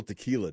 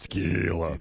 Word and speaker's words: tequila: It was National Tequila Day yesterday. – You didn't pronounce it correctly. It's tequila.